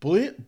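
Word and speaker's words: Bulleit: And I've been, B-U-L-L-E-I-T. I couldn't pronounce it. I was like, what is it? Bullet? – You know what? You're right.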